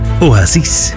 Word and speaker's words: Oasis: Of op zijn Spaans uitgesproken, Oasis. Want het is een Spaanse serie over een medewerkster in een hotel die verdwijnt.